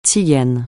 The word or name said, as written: tsigane